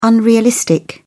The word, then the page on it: unrealistic: British English